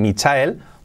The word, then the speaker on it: Michael: Michael, fíjate cómo pronunció, mm, Michael, Michael, con ese L muy al final muy sutil, Michael might come tomorrow.